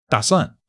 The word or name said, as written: da3 suan4